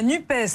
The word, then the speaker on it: NUPES: Comment on prononce ? – De la NUPES. – De la NUP. – Le NUPES. – La NUPES. – La NUP, comme on dit maintenant. – De la NUP, moi je dis la NUP, je sais pas.